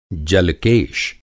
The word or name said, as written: जल-केश